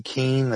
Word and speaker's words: Keene: Keene. That's spelled K-E-E-N-E.